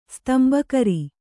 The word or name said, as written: ಸ್ತಂಬಕರಿ